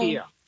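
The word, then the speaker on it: Türkiye: Turkiya.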